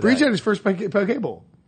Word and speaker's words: poke: It's poke when you want to sound fancy on air. Oh, okay. Poke bowl. – Does it rhyme with croquet? – It does.